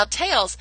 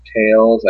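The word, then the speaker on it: Taeles: Okay, it's spelled T-A-E-L-E-S. I've heard it pronounced Tails. I've heard it pronounced Tails. All sorts of European folks tend to go with the second option, and it both sound really, really cool to me, but the name, it comes from World of Warcraft.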